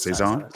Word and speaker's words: saison: Sun's out, hops out, it's a saison. I think I'm saying that correctly. I never know how to say that. – I think it's saison. Saison. – Saison. Saison. There you go.